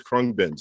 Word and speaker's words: Khruangbin: So, uh, real quick before I choose my next one, producer Mary Beth has just messaged us and saying that you guys are saying it right. It is Khruangbin.